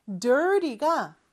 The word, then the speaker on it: dirty: 자, dirty. 발음할 때는 저 T 발음을 좀 약하게 하죠. 그래서 water가 water가 되는 것처럼 저 T 발음은 real과 비슷하게 혀가 입천장에 살짝 닿았다 떨어지는 그러한 표현입니다. Dirty, dirty 이렇게 발음하면 돼요.